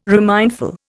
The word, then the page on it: remindful: Indian